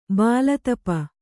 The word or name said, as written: ಭುಲತಾಪಿ